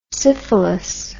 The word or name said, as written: syphilous